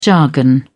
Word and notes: jargon: British English

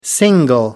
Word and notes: single: American English

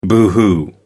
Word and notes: boohoo: American English

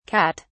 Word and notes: kat: British English

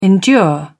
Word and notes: endure: British English

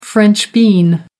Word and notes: french bean: American English

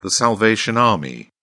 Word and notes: salvation army: British English

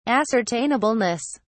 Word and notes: ascertainableness: British English

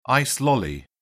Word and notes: ice lolly: British English

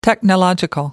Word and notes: technological: American English

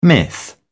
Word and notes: myth: British English

